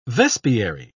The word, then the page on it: vespiary: British English